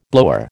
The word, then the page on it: blower: American English